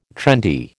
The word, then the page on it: trendy: American English